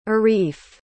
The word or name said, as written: arif